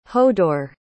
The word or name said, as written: hodor